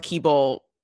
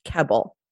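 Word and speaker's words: Kebbel: Isn't there two E's? Isn't it a long E sound? – No, it's K-E-B-B-E-L.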